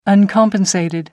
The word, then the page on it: uncompensated: American English